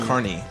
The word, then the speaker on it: Kearney: Like worker at a carnival. – It's not spelled like that, but it's pronounced Kearney.